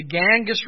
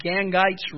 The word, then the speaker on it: Gangites: It's the Ganges River is one pronunciation of it. The other one is Gangites River.